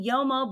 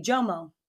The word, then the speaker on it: JOMO: No, but you're saying YOMO, but for... – It's with a J. – Like, it's with a J. JOMO. – JOMO. That's how you say it. – JOMO. – YOMO is with a Y. It's JOMO.